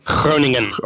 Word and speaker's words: Groningen: Groningen. – Okay, yeah, I could soon type. – Yeah, a Scottish, It's a Scottish CH at the start.